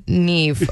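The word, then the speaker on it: Niamh: Like, Neam McDonald? – No! – No, it's actually Neve, okay? The M makes a V sound and the H is silent.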